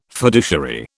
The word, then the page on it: fiduciary: British English